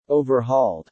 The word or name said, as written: overhauled